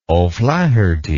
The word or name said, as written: oflaherty